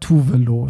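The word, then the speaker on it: Tove Lo: Tovlo. Ah, nee, die, die, spricht man ja, was habe ich gesagt? Tuvelo spricht man die eigentlich richtig aus.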